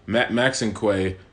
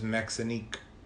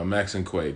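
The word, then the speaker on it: Maxinquaye: That's how you say it? – Yeah. – I always thought it was Maxinique. – No, Max and Quay, because it's a mixture of a few things.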